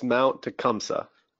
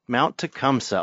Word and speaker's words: Tecumseh: So it's Mount Tecumseh. – Mount Tecumseh, okay.